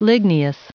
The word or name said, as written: ligneous